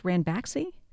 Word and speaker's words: Ranbaxy: Company called, is it pronounced Ranbaxy? – Yes, that's right.